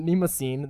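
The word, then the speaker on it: Mnemosyne: because I can never pronounce that word. – Mimosine. – There you go.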